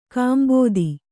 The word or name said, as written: ಕಾಂಬೋದಿ